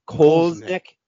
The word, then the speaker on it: Koliznik: Koliznik, I'm probably saying it wrong, right? – Koliznik. – Oh, that's even more American sounding, but okay. Koliznik it is then.